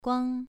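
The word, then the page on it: guang: tone 2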